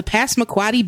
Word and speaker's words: Passamaquoddy: On August 6th, 1867, an old Ned specimen was killed off the Passamaquoddy Bay.